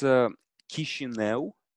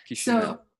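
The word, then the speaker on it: Chișinău: So it's Chisinau. – Chisinau? – Yeah. Perfect. – Okay. Chisinau.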